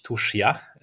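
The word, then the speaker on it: Tuscia: Ale zobaczyliśmy taki region, e, on się nazywa Tuszia, Myśmy przez pierwsze tygodnie ludzie nam na Instagramie zwracali uwagę, że źle wymawiamy, bo kto by się spodziewał, e, że trzeba mówić tuszja, e, pisze się tuscja.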